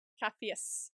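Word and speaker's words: Caphéus: Kafius. Did I say that right? – Kafius. – Kafius. – With an S. What? With an S. Kafius. Kafius. – Kafius. – At the end. C-A-P-H-E-U-S.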